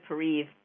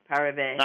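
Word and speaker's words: pareve: I only recently figured out what that word meant, so I didn't know whether it was parev, pariv, parave. – I don't know. I say parev because it's spelled that way, but other people, you know, people Yiddish speakers say different things.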